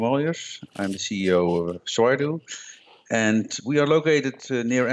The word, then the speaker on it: Swydo: tool called Swydo. Have I pronounced that right? – You pronounced it right. – Swydo. – Yes. Swydo.